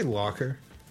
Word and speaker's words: Locher: How do you pronounce that name right Where? there? – I'd say Locker. Wes Locher, yeah, yeah, okay.